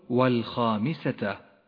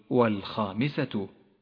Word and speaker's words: والخامسة: فالتاء المربوطة مفتوحة وليست مضمومة قل والخامسة، ولا تقل والخامسة.